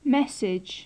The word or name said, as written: message